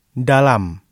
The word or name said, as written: dalam